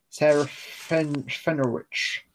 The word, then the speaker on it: Serafinowicz: Oh, Peter I can never say his name. – Yeah, Seraph Ferenowitz. – I can hear it in my head, and it's just my mouth can't make those shapes. Peter Serafinowicz. – Serafinowicz.